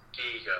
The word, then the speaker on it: Giger: So would you say the set designs or the original concept artwork was done by, would you say Geiger or Giger? – I think it's supposed to be pronounced Giger, I think.